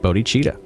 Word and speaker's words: Bodhicitta: Bodhi, Bodhicitta. And that's pronounced Bodachita.